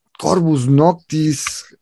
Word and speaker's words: Corvus Noctis: Y pues esta noche tenemos a la gente de Corbus Noctis. Em, ¿Sí lo pronuncié bien? Sí, sí, es sí, así, ¿verdad? – Sí, sí, está bien pronunciado.